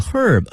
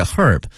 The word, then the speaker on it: herb: 英国人会把这个 H 这个 herb 这个 h 这个音呢给念出来 herb，但是美国人啊会把这个第一个字母 H 给它吞进去，直接念成 herb 啊。